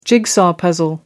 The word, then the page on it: jigsaw puzzle: British English